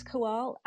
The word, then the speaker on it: Kawal: My name is Kowal, and it's spelled K-A-W-A-L.